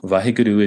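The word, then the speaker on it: Waheguru: This is, am I saying this right? This is Vaheguru? – Vaheguru is the term that we use for divinity.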